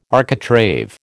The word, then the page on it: architrave: American English